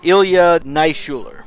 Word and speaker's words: Ilya Naishuller: Yeah, Ilya Naishuller. – All right, you attempted and succeeded.